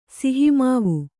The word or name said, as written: ಸಿಹಿಮಾವು